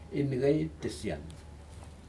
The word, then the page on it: incaitisian: Aneityum